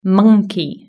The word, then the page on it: monkey: British English